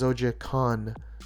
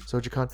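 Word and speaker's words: Zojaqan: And by the way, thank you for the folks at Vault for correcting me on how to properly pronounce it, because it's spelled weird. It's Z-O-J-A-Q-A-N, but it is Zojikon. Zojikon.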